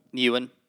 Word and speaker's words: Ewan: Like, Ewan McGregor, right? Is that how you pronounce his? – Ewan.